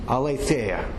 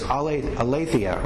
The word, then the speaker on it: aletheia: The Greek word for truth, aletheia, who knows how to say it, right? It's 2,000 years ago, but it's aletheia or aletheia.